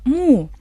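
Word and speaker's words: му (木): Слово «му» произносится также в 4-м тоне.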